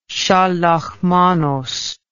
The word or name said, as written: shalach manoth